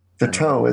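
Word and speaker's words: Tautou: I forget how to say her last name, Teto or whatever. Emily. – Teto, isn't it? Right. – Yeah, it's Teto. – Teto.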